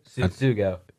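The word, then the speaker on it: Tsutsugo: Sutsugo.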